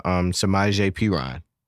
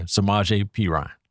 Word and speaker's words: Samaje Perine: We got Samaje Perine. – Yes, that's Joe Mixon's backup. – Oh, I said that right? – You did.